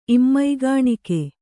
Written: ಇಮ್ಮೈಗಾಣಿಕೆ

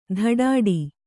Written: ದಡ್ಡಿ

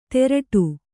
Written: ತೆರಟು

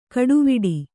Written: ಕಡೆವೀಡು

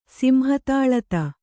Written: ಸಿಂಹತಾಳತ

